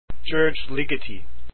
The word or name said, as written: ligeti